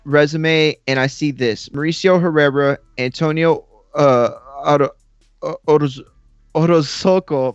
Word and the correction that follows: Orozco: fuck, I butchered it. – Orozco, my bad, Orozco, like un pollo.